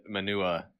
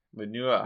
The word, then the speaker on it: Manua: I would be inclined to say that that Manua. That sounds like a someone from Boston pronouncing manure. – Manua.